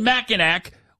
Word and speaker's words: Mackinac: It's spelled, why do they spell it Mackinac when it's Mackinac? It doesn't make any sense.